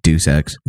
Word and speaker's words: Deus Ex: The the Illumi- Deus Ex is that how it's pronounced? Yep. I just pronounce it Deus Ex. Deuce. Yeah, I always did too.